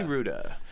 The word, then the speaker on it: Rouda: His name's Harley Ruda. Harley Ruda. – Oh, yeah. – How do you spell that? – R-O-U-D-A, like Gouda with an R.